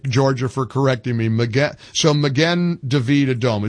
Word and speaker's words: Magen David Adom: So you got it right on the second time. It's Magen David Adom.